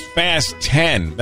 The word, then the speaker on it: Fast X: Fast X is meant to be pronounced Fast 10. That's a movie coming out. The Fast and the Furious saga. The director says it's a pun, as in, quote, fasten your seatbelts.